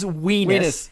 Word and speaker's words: Weenus: The word is Weenus. He named the rocket Weenus. It rhymes with part of your anatomy.